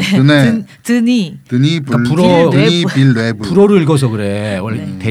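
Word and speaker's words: Villeneuve: 드니 빌레브. 빌레브. – 네, 이젠 좀 외울 때도 되지 않습니까 아, 외웠는데 발음이 안 되네. 뽕네프의 네. 연인들 이런 것처럼 빌레브.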